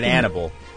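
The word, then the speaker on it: Annable: Because his name is Dave Anobly? – Ooh. – From a TV show called Brothers and Sisters. – Oh. – He's not right for her. – I think he pronounces it Annibal.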